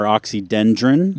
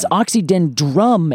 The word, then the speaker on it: Oxydendrum: Yeah, I think I bet the two biggest mispronunciations are oxydendron. – Yes. – And arboretum. – Yes, you literally called it. That's exactly it. So it's oxydendrum, as in I hit a drum and I make drum sounds.